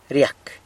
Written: ryac

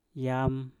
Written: yam

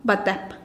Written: batep

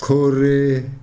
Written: xhoree

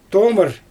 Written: tomɯr

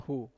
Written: hu papeda

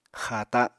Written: xaata

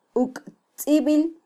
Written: uktzibil

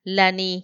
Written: lani

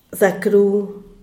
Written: zacru

